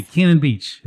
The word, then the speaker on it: Cannon Beach: But I'm not going to say that today, because we have a representative, the Executive Director of the Cannon Beach Historical Society.